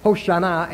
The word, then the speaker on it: Hoshana: The word Hosanna in Greek, which comes from the Hebrew word Hoshana, Hoshana actually is how you pronounce it, means save or deliver us from evil.